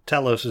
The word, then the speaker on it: Talos: Talos is just how you pronounce that.